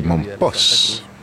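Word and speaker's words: Mompox: But whichever way you spell it, you say Mompos.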